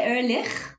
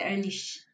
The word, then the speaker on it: Ehrlich: So I say Ehrlich. It's actually a German origin, and the Germans tend to say Ehrlich.